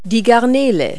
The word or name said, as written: shrimp